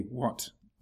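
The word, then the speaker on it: Wat: So What, W-H-A-T, a What, W-A-T, a What, which is the Thai pronunciation of the Pali word Avasa, which means a monastery.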